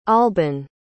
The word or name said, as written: alban